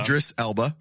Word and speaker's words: Idris Elba: Idris Elba.